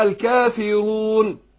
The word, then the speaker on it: الكافرون: كتير واحد بقى يكون بيقرا كده ويعني متسلطن قوي في القراءه، قل يا ايها الكافرون، ما ينفعش طلع من ايه بخنفه كده من الخيشوم، وده خطا. لا الكافرون الكافرون، بحيث انت لو كتمت الخيشوم الالف في اثناء النطق لو نطقها خطا هتطلع ايه؟